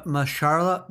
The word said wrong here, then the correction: Mahershala: how do you say it, guys? Oh, Mar- Mar- uh, Mahershala, Mahershala, Mahershala Ali.